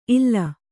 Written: ಇಲ್ಲ

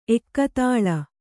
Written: ಏಕತಾಲ

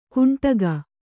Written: ಘುಂಟಕ

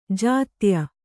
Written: ಜೊತ್ತಾಯ

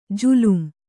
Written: ಜುಲುಂ

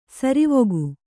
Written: ಸರವಕ್ಕಿ

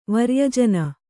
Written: ವರ್ಯಜನ

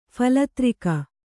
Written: ಫಲತ್ರಿಕ

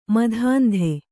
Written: ಮತಾಂಧ

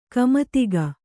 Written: ಕುಮುದಿಕೆ

